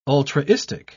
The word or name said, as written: ultraistic